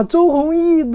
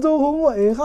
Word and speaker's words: 周鸿祎: 我看呢，我们的喜马拉雅的群友给我留言也说，哈哈哈笑我说，哎呀，把周鸿祎读成周鸿祎，哈哈哈，太没有文化了啊！